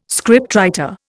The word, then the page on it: scriptwriter: Indian